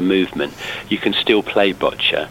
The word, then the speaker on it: Boccia: I hope I'm going to pronounce this right. What is Boccia? – It's Boccia. – Boccia. – Boccia, yeah. – I knew I was going to say that wrong.